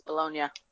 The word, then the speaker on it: Bologna: It's Bologna.